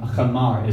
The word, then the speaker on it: chamar: Veha chamer, chamar is how you say it.